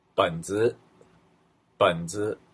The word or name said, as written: benzi